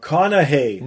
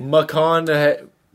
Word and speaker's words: McConaughey: Oh my God, McConaughey. – McConaughey.